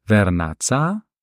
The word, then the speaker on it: Vernazza: Also es sind ganz konkret das Dorf Rio Maggiore, dann haben wir noch Manarola, Cornelia, Vernazza und noch Monterosso al Mare. Habe ich das richtig ausgesprochen? – Esatto, sì, no, le pronunciati bene.